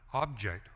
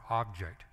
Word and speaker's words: object: So for example, we got the word object, O-B-G-E-C-K. But it makes a difference how you pronounce it. Object or object means two completely different things.